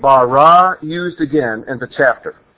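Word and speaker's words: bara (ברא): In Hebrew it's spelled Beit Resh Aleph, bara. In English, B-A-R-A will do, bara.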